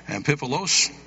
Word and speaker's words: Amphipolis: The Bible says, now when they had passed through Amphipolis, Pastor, help me on that pronunciation. Amphipolis, okay, and Apollonia, they came to Thessalonica, where was a synagogue of the Jews.